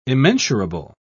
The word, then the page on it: immensurable: British English